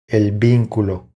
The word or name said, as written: El vinculo